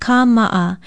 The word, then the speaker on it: kāma'a: Be sure to spell it with a stress mark, a kahako over the first A, and an okina between the last two A's. Pronounce it kāma'a.